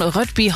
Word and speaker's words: Rødby: Der Fehmarn dann mit dem dänischen Rødby Horn verbinden soll. – Das hast du aber schön dänisch ausgesprochen.